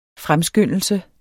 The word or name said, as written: fremskyndelse